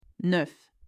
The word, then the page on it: 9: French